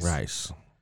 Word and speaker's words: Reis: R-E-I-S. Ivan Reis. – Rice.